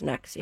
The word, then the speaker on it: NXIVM: But it's NXIVM, but pronounced Nexium.